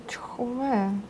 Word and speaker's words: Чхве: Чхве. Или Чхве. – Окей. – Чхве Сунхи. – Потому что я вижу, у нас пишут по-разному. – Чхве.